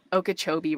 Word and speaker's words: Okeechobee: I could not believe you pronounced Okeechobee right the first try.